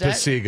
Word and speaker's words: Pacyga: What uh, I would, I guess we might pronounce it Pacyga. It's Ryan is Pacyga is his name.